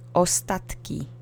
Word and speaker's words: ostatki: The next word is ostatki, which means remnants or leftovers or the last bits of something. Accent is on the middle syllable. Let's say it together three times. Ostatki. Ostatki. Ostatki.